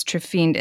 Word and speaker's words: trephined: I think it's trephined, and then it's trepanation. Trephined is T-R-E-P-H-I-N. So I think it's trephined your skull, but it's called trepanation.